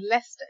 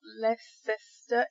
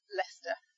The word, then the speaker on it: Leicester: So if you see this, you just pronounce the S-T-E-R, so it's a st sound. So we've got Worcester. It's not Worcester, it's Worcester. And Leicester, it's not Leicester, it's Leicester. So you really ignore the C and the E. And then you just get Worcester and Leicester.